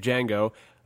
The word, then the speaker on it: Django: He's actually a bounty hunter, and he's looking for this particular slave, Django. The D is silent, by the way.